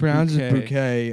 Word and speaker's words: Bucket: Her last name's Bucket, but she pronounces it Bouquet.